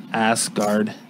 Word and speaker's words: Asgard: All I know is you guys can't pronounce Asgard for nothing. Asgard. Asgard, like Guardian.